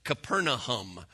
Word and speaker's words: Capernaum: Capernaum is how we pronounce it, but that's actually not how it's said. It's actually said Capernaum.